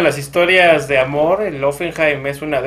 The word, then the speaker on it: Hoffenheim: El Olympique de Lyon, el Shakhtar Donetsk y el, ¿cómo se dice? Hoffenheim, el alemán. Hoffenheim.